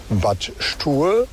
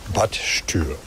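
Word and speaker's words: Bad Stuer: Und etwas weiter südlich gibt es den Ort Bad Stur. Der wird mit UE geschrieben und deshalb sagen die Leute häufiger Bad Stür.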